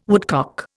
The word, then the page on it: woodcock: Indian